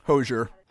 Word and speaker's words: Hozier: Hozier.